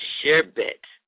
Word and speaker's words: sherbet: The incorrect pronunciation is sherbet. The correct pronunciation is sherbet. Like share and bet. Sherbet. Eight. Alright. Not the incorrect pronunciation, aw, like A-W-W hyphen R-E-E, R-E-E.